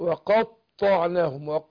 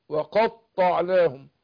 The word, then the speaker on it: وقطعناهم: يبقى ناخد بالنا من تشديد الطاء. وقطعناهم، وقطعناهم. وهنا عندنا عشرة، اخد بالي من تسكين الشين، ما قلتش عشرة، لا عشرة عشرة.